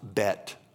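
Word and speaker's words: bet (בית): Listen, I'm gonna go to the letter B in the Hebrew alphabet. It's pronounced bet, okay?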